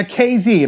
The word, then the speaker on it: KZ: Now, KZ, and by the way, it's pronounced KZ like Jay-Z.